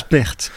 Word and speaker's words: Pärt: On prononce Pärt, P-A-T-R-T.